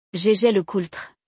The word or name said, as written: Jaeger-LeCoultre